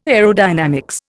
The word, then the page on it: aerodynamics: Indian